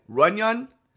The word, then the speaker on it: Runyon: And let me know, which I know you will, internet, how do you actually pronounce that? R-U-N-Y-O-N. Runyon? Runyon? – Runyon? – That's right.